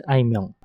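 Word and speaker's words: あいみょん: 对，如果是日文的话是爱缪。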